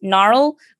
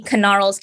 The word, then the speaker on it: Knarl: They because it's spelled K-N-A-R-L, everyone pronounces it just Gnarl, but it's supposed to be Knarl's Candy Compendium for the alliteration.